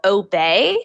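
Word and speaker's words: Obé: from an online streaming company called Obey. And it's spelled O-B-E.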